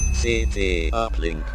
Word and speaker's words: c't uplink: CT Uplink.